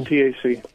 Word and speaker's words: MTAC: Um, did I say that right? MTC. – Tactical? MTAC. MTAC.